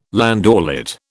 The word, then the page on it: landaulet: British English